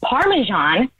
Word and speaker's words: Parmesan: It's pronounced Parmesan. And that's actually a pet peeve of mine when people pronounce it wrong. How are you even saying it? Parmesan cheese? It's not Parmesan cheese. – It's Parmesan. It's Parmesan cheese.